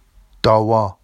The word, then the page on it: dɑwɑ: Vafsi